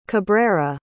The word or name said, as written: Cabrera